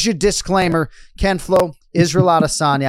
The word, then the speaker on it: Adesanya: And I vowed to not have a pronunciation conversation today because, yes, you can go Adesanya or Adesanya, right? But the one thing you cannot do is have the H sound.